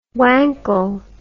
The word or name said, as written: wankle